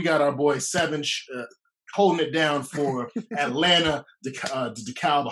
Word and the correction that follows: DeKalb: I hope I pronounced that right. DeKalb, come on, DeKalb. – I'm sorry, DeKalb.